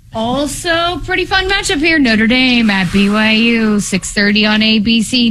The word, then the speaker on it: Notre Dame: I believe it's pronounced Notre Dame.